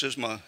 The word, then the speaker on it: schisma: Now, this word that Paul uses, that we translate division, schisma, or sometimes pronounced schisma in Greek, means something a little bit different, unfortunately, than how we translate it.